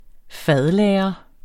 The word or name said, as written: fadlagre